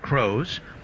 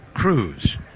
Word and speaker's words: Croes: 0-1 on Dayson, D-A-Y-S-O-N. Cruz, spelling it C-R-O-E-S. Looks like Crows, pronounced Cruz.